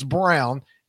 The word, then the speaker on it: Braun: It's somewhere in that family, they decided to take something that clearly spells Braun and say it's Brown.